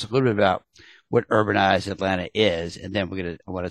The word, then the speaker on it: UrbanizeATL: Today, we've got Josh Green, who is with UrbanizeATL. We're going to talk about what's going on there. Am I saying it right? Should I say Urbanize Atlanta or Urbanize ATL? – I usually say Urbanize Atlanta.